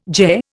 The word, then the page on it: Ge: Indian